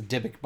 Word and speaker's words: Dybbuk: Dybbuk.